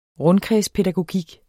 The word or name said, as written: rundkredspædagogik